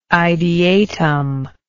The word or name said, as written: ideatum